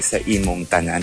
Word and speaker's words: sa imong tanan: Yes, maayong buntag sa imong tanan. How do you say it? Is that correct? – Sa imong tanan. – Sa imong tanan. – Yeah. Yes.